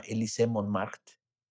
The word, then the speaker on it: Élysée Montmartre: Un lugar que se llama Élysée Montmartre. Bueno, así se pronuncia, ¿no? Pero se escribe Élysée Montmartre, ¿no? – Uh-huh.